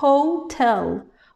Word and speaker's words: hotel: Un hôtel, un hôpital, euh, mais en anglais, ce H est important. Hotel. On le prononce.